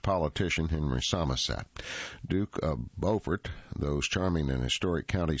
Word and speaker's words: Beaufort: Located on Port Royal Island in the heart of the Sea Islands that stretch from Jacksonville to Charleston, Beaufort, pronounced Beaufort, is the county seat of Beaufort County and is known for its lush low country landscape, antebellum architecture, and genteel southern hospitality.